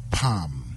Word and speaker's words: palm: PALM. The L is silent, it's not pronounced.